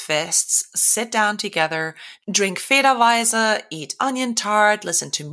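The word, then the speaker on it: Federweißer: Federweiser. It is spelled Federweisser.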